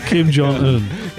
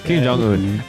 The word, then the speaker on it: Kim Jong Un: Kim Jong Un. – Kim Jong Un.